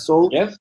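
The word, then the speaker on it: Soule: Charles Soul. – Charles Carlos Alma, pero sin alma. Charles Soul. se escribe Charles e, S-O-U-L-E. Charles Soul.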